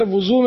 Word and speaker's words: وضو: میں یہ پوچھنا چاہتا ہوں کہ وضو کرنے کے ٹائم بولنا چاہیے یا نہیں بولنا چاہیے، یا بولنے پہ وضو ٹوٹ جاتا ہے؟ – وہ صحیح اس کا تلفظ ہے وضو، واو پر پیش ہے، وضو نہیں وضو، زواد ہے اس میں.